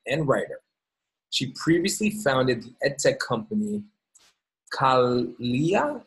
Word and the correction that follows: Kalia: Am I pronouncing that correctly? – Kayla, but that question comes up a lot.